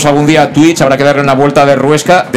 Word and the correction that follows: rosca: de, rosca, no de ruesca, sino de rosca una vuelta de rosca, ahora lo he dicho bien, y, hacer incluso la traducción con gestos, eh, para, también sordomudos.